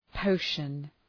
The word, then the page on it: potion: ‘pəʋʃən